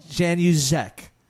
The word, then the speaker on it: Januszek: Jan uh, Januszek. Yeah, that's it. Well done.